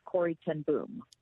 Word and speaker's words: Corrie ten Boom: The first thing I learned being in Holland for the last 3 days is it's pronounced Corrie Ten Boom. – Oh, okay. – Which I did not know. – Like Carnegie.